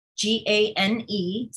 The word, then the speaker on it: Gane: That's probably the best way to reach me, but you can find me on Twitter at Kim, G A N E, spelled like my last name, Kim Gane, G A N E, possible.